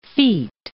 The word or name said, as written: feet